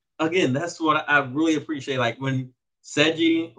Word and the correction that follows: Senji: or, what's his name, Seji? Let me be accurate. When Senji is actually butchering the animals,